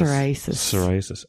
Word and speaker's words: Psoriasis: Psoriasis. Is it with a P? – Sorry, is it a P, but the P is silent.